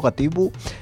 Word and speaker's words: Gatibu: Y en este de los Gabitu, como digo yo, Gatibu, sale uno que, no sé, podría parecer lastres mismamente, guapamente.